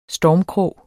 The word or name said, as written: stormkrog